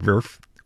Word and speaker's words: Werf: That's spelled with a W, but I believe it's pronounced Werf with a V.